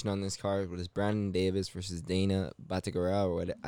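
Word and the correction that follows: Batgerel: They were saying it Batkaril.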